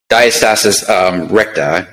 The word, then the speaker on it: diastasis recta: Diastasis recta. Did I say that right? – Yeah, diastasis recta. Everybody pronounces it differently, man.